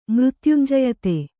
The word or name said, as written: ಮೃತ್ಯುಂಜಯತೆ